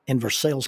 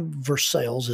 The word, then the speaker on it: Versailles: Now, I don't know why Versailles is pronounced Versailles instead of Versailles.